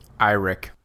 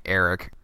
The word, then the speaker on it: Eirik: Einrich. What's his name? – Eirik. I mean, that's one way to pronounce it. Uh, we were saying Eric earlier, but um, yeah, Eirik is another way to pronounce it.